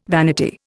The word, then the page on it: vanity: Indian